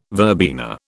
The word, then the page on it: verbena: British English